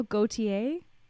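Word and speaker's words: Gaultier: Also, apparently, Gautier, am I pronouncing that right? Jean-Paul Gautier? – Yeah.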